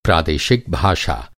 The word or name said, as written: प्रादेशिक